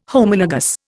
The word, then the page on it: Homologous: Indian